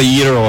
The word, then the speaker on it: gyro: You probably hear Canadians call it a gyro, G-Y-R-O. How do you say it? – Gyro. Well, gyro. Some people can pronounce it, but which is okay. But if you can do the E, that's okay. That's cool. Gyro.